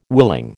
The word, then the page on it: willing: American English